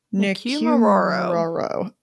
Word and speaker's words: Nikumaroro: Nikumaroro.